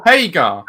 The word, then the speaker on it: Hager: You pronounced his name wrong. – Sorry. – It's not Swagger. Sammy Hager? – No. Whoops, wasn't paying attention. Never mind. – Did him. – Jake Hager versus Dustin, MJF. – No, I told you, Hager. – Okay. – That's how it's said.